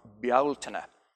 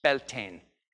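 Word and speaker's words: Bealtaine: It's the location of the fire festivals of Beltane. Now, I say that with the Irish pronunciation. You might know it as Beltane.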